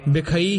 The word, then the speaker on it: बिखई: जन्म जन्म के इसे तरह उच्चारण करना, जन्म जन्म के इसे जमक ला के बिखई तुम तारे, बिखई ही उच्चारण करना, बेखई नहीं कहना, बिखई तुम तारे सुमत ते मम्मे ते अधिक दा प्रभाव देना, सुमत संग तुम्हारे पाई।